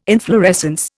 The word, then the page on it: inflorescence: Indian